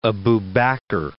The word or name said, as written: Aba Bakr